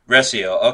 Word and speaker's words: Recio: Recio, okay.